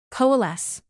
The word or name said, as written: coalesce